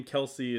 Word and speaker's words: Kelce: Kels is the nickname. Kelsey is how you actually say it.